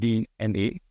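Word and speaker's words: DeNA: It's written as Dina, but it's pronounced D-N-A, which is a huge public listed entity out of Japan.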